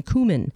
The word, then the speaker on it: cumin: And it's the proper pronunciation, cumin.